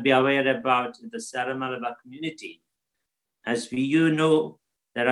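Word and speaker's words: Syro-Malabar: Ciro Malabar.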